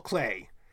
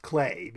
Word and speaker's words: Klee: And so as soon as we could read letters, we knew it was pronounced Klee, because she would take us to the art museums,